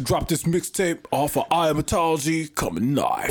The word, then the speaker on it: iamatology: Iamatology. Yeah, that's it. That's how you pronounce it.